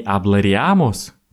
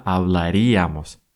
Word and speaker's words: hablaríamos: Не аблариамус, как в начале может захотеться, а именно облариамус. Помните, всегда ударение будет на ия.